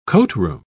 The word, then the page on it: coatroom: British English